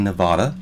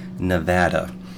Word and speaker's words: Nevada: Now, before I go any further, I know most of us say Nevada, but the correct pronunciation is actually Nevada. In fact, people in the state of Nevada go so far as to put that little smiley face thing over. I forget what it's called. Looks like a small U. That makes it a short A, Nevada.